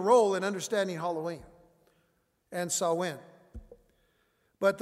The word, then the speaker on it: Samhain: But according to the Encyclopedia Britannica, in ancient Britain and Ireland, the Celtic festival of Samhain, it's spelled S-A-M-H-A-I-N, sometimes you pronounce it Samhain, but it's basically pronounced Samhain or Savin, which means end of summer, was observed on October 31st.